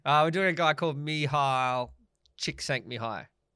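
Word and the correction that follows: Mihály Csíkszentmihályi: Mihai Chick Sent Mihai. – Did you do the little YouTube? – I did. – This is how you pronounce it.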